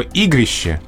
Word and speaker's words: игрище: Есть два слова в русском языке. Есть слово «игрище», угу, с буквы «е» на конце и с ударением на первом слоге.